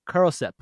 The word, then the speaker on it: cordyceps: He found God in the cordyceps.